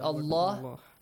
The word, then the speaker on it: Allah: That lam in the word Allah, which is a heavy letter here. It's not a heavy. It's pronounced heavily.